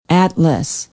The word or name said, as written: atlas